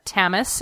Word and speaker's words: Tamas: I was well into chapter 3 before I realized, Tamas is pronounced like Thomas or Tom.